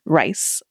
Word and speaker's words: Rice: My first name is spelled K-E-S-H-I-A. So Keshia Rice, like the food.